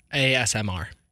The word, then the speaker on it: ASMR: AMSA. Oh, so wait, ASMAR? – ASMR. – ASMR. – I mispronounced.